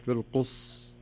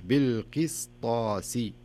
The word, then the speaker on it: القسطاس: فتصبح بالقص والصحيح بالقس تنطق السين بحيث أنك يعني يسمونها إتمام الحركات أو إتمام الحروف بصفاتها ومخارجها بالقسطاسي ما أقول بالقص لا بالقس طاسي وكذلك المستقيم يعني حتى يمكن في الصلوات نسمعها بحكم أنها في سورة الفاتحة نسمع بعض الناس يقول مستقيم والصحيح المستقيم.